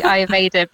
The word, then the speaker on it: Ayurveda: That could lead me off onto a tangent with the Ayurveda, because that's obviously...